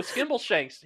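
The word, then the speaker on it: Skimbleshanks: Skimble Shanks. Okay, you did say it right.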